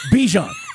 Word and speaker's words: Bijan: And by the way, we found out how to say his name last night, or at least how he likes to say it, right? Because at first it was, we were saying Bijan. – Yeah. – Now it's Bijan. All right, which one is it? Bijan. That's what it is.